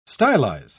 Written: stylize

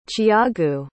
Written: tiago

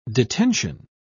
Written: detention

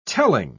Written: telling

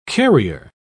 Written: carrier

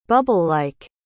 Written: bubble-like

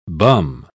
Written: bum

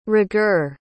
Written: rigueur

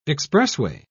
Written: expressway

